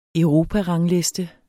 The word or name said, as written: europarangliste